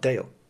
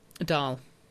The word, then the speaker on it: Dáil: Incidentally, is that how you pronounce it? Dáil? It's not Dale. – Dáil.